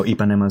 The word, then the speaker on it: Ipanema: That's right, The Girl from Ipanema, as it's also pronunciated sometimes. – Well, Ipanema is the correct pronunciation, I'm just saying.